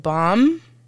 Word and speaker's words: Baum: Frank Baum.